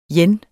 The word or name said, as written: yen